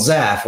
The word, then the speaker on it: Zhaff: And in that, there's a character called Zaff, which is Z-H-A-F-F, and that was always his name. And then I didn't even put it in the pronunciation list because I didn't want to spell it Z-A-F-F because that looks stupid.